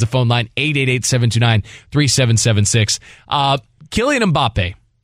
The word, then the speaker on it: Mbappé: And now it's like, with the way the accent is on the end of his name, you gotta French it up a little bit. Mbappe.